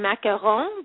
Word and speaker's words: macaron: Well, you know, as it turns out, French macaroons, in French they pronounce them macaron, but just for ease of the listeners more familiar, I'm going to call them French macaroons.